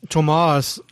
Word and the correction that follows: Tuomas: I guess I'm saying it correctly. Tuomas.